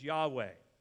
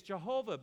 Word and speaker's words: Yahweh: Sometimes it'll be said as Yahweh. Others say it as Jehovah, but that's a mispronunciation of the actual name. Jehovah is not the actual pronunciation, although people take it like it is.